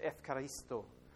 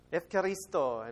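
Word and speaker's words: Evcharisto (ευχαριστώ): So if you go to, they pronounce it Evcharisto now. EU is like Ev. Anyway. Evcharisto, it means thank you.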